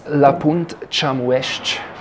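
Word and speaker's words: La Punt Chamues-ch: Du bist ja nicht aus St. Moritz selber, sondern aus La Punt Chamuest. – Habe ich das jetzt richtig ausgesprochen? Genau.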